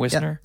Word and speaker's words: Wisner: That's how you say his name? Wisner? – Yep, it is, yeah.